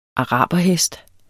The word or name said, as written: araberhest